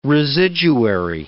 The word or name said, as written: residuary